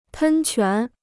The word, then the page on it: 喷泉: pēn quán